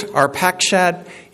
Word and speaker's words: Arpachshad: And so if you're thinking, isn't it Terah? Or are you sure it's Arpachshad? Yeah, according to the Bible pronunciation app, I got these.